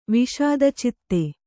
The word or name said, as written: ವಿಷಾದಚಿತ್ತ್ತೆ